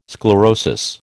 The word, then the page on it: sclerosis: American English